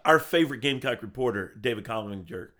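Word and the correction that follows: Cloninger: I can never say his name right. – Kloniger. – Yeah. – Kloniger, that's probably good.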